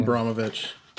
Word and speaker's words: Abramovich: Abramovich.